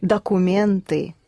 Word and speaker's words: документы: Документы? – Да. Only the first or is not accented, so pronounce it the Russian way. – Документы. – Pronounce it more Russian, as in da.